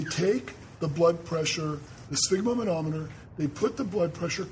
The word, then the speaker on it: sphygmomanometer: So everyone focus, sphygmomanometer. One more time, sphygmomanometer.